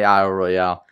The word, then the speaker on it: Isle Royale: It's definitely Isle Royale. It's got to be Isle Royale. It's spelled with an E at the end.